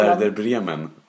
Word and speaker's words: Werder Bremen: Och sen så skulle Werder Bremer vinna mot... – Werder Bremer. – Alltså jag kan inte uttala... – Werder Bremen. – Ja, Bremen tror jag.